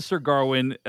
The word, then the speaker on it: Gawain: But yes, he pronounces Sir Garwin. I'm going to say Sir Gawain, because I just can't shake that. – Yeah. – And I think people will have their own... draw their own conclusions about how they're going to pronounce it. Alisha, what's your preferred pronunciation? – I say Gawain.